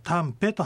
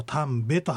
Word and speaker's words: タンペ: ですから、タンペと発音してもタンベと発音してもいいわけです。